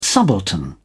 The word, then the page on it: subaltern: British English